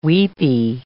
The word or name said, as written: weepie